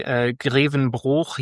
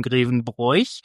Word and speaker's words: Grevenbroich: Das ist wahrscheinlich hat das irgendwie so mit regionalen Besonderheiten zu tun, ob das mal ein Verschluckbuchstabe oder mal nicht ist, so wie Grevenbroich ja nicht Grevenbroich ausgesprochen wird. Da wird das I ja einfach auch unter den Tisch fallen lassen, außerhalb von Grevenbroich. Für den Rest Deutschlands heißt dieser Ort ja Grevenbroich.